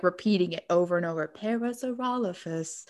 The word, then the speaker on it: Parasaurolophus: I was pronouncing them wrong since I was a kid. I've been pronouncing them Parasilophagus this entire time.